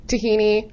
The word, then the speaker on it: tahini: Which is only chickpeas, tahini, I said that right? – Yep.